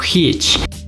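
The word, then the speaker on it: hygge: Por ejemplo, la cultura danesa tiene la palabra que se pronuncia como hitch y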